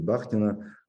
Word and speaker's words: Бахтина: И я лично сейчас очень увлечен творчеством Михаила Михайловича Бахтина, или Бахтина, опять же, с ударениями у нас по-разному, я люблю говорить Бахтина, а, где есть измерение другого.